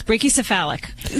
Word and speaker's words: brachycephalic: Is that one of those, what do they call that, brachycephalus, right? Yeah. Did I say it right? – Pretty close. Brachycephalic. We could say pug-like, you know, that always works.